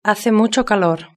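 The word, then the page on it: hace mucho calor: Spain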